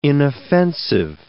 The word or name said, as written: inoffensive